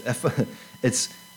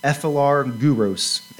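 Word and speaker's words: philargyros: It's ephilargyros, ephilargyros, ephilargyros, kind of like gyros or gyros.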